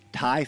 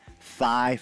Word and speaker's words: Thai: He loved Thai food, but he would pronounce it thigh food.